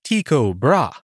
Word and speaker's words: Tycho Brahe: Tycho Brahe, or Tycho Brahe, as it's pronounced in Danish, was born in Denmark on December 14th, 1546.